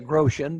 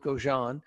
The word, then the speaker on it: Grosjean: Grosjean is pronounced. Mm-hmm. Some people say Grosjean, but we'll go with Grosjean.